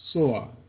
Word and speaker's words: Tyre: But that city called Tyre, pronounced Soar, as in Zoar, the city that Lot fled to in fleeing from the destruction of Sodom and Gomorrah.